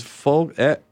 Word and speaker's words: Foulkes: I think her Twitter is Folks. It's spelled like F O U L K E S.